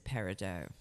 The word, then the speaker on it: peridot: Yes, peridot is, I mean, generally accepted in the gemological world, peridot is the way that you would pronounce it. So we're not going to turn you away if you say peridot to us, but generally the way to pronounce it is peridot.